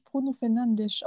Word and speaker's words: Bruno Fernandes: Warte, der wird, glaube ich, Bruno Fernandes ausgesprochen. Das interessiert mich einen Scheiß, ganz ehrlich. Weil er portugiesisch ist.